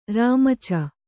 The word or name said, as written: ರಾಮಚ